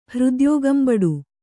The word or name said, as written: ಹೃದ್ಯೋಗಂಬಡು